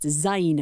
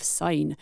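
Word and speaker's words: Syne: Auld Lang sign pronounced zine instead of sign.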